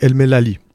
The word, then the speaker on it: El Melali: Juste c'est El Melali, pas El, Melali. El Melali, El Melali. Voilà, il n'y a pas de i.